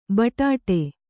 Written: ಬಡ್ಡು

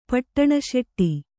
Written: ಪಟ್ಟಣಶೆಟ್ಟಿ

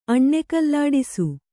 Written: ಅಣ್ಣೆಕಲ್ಲಾಡಿಸು

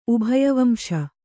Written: ಉಭಯವಂಶ

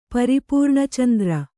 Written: ಪರಿಪೂರ್ಣಚಂದ್ರ

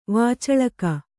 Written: ವಾಚಳಕ

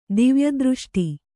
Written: ದಿವ್ಯದೃಷ್ಟಿ

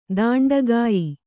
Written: ತುಂಡುಗೆಯ್

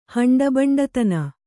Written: ಹಂಡಬಂಡತನ

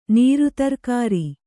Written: ನೀರುತರ್ಕಾರಿ

